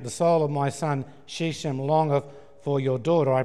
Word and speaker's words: Shechem: And Shechem,